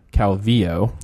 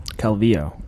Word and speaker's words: Calvillo: First, let's pronounce her name right. Calvillo. – Calvillo. – Yes.